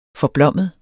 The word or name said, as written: forblommet